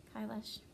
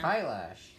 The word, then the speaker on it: Kailash: Lives on Mount Kailash. Again, I could be saying that wrong. I don't know. – Where's that at? – Correct me. Kailash. – No, that's it. That's right.